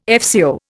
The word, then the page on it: FCO: Indian